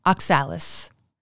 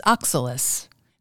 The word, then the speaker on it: oxalis: Sometimes a mispronunciation occurs because of a misplaced accented syllable. "That rampant plant which is the bane of my spring garden and of our whole town, I've always heard called oxalis." Yet the correct pronunciation is oxalis.